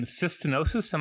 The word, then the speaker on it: cystinosis: So the condition cystinosis, am I saying it right? Cystinosis. – That's right.